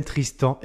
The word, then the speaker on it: Tristant: J'accueille donc euh, Goulven Tristan. Est-ce que je prononce bien ton nom déjà, Goulven Ouais, très bien. Avec le, prénom, c'est Goulven et le nom, c'est Tristan.